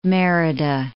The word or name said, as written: Merida